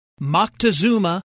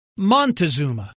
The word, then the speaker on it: Moctezuma: And now, let's explore the difference between the Aztec names Moctezuma and Montezuma. Moctezuma, born in circa 1466, has many variant spellings and pronunciations, including Montezuma. North Americans are generally more familiar with Montezuma.